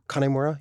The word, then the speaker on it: Kanimura: I'm pronouncing it wrong when I say Kanimura. It's Kanimura. – Kanimura, yeah. – Like Connie.